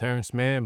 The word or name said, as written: Terance Mann